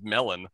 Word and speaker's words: mellon: It is indeed spelled melon, M-E-L-L-O-N. but in Sindarin, which is the name of the language that Tolkien comes up with, it is pronounced Melon, which is what Gandalf tries to say.